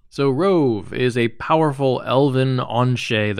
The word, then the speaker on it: Rhuobhe: Yes, I pronounced that correctly. Rhuobhe with a V, Manslayer.